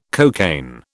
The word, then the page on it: cocaine: British English